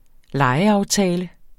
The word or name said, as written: legeaftale